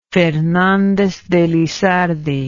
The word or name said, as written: Fernandez de Lizardi